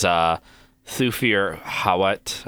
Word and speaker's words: Thufir Hawat: It is Thufir Hawat.